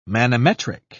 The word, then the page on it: manometric: British English